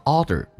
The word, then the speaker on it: order: In 跟 order 有一个连，记住 order 这个单词很多人读成 order， No，美式发音叫 order，两次都卷舌。